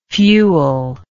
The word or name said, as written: fueller